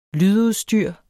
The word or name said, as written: LYDUDSTYR